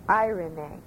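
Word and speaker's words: irene (εἰρήνη): I looked up the word in Greek, and I wrote it there for you, and it's pronounced, as closely as I can do it, irene.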